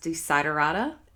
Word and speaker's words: Desiderata: So, as someone who's always trying to improve their own testing skills, can you tell me a little bit about Test Desiderata and if I pronounce that correctly? – Uh, yes, you, you got it.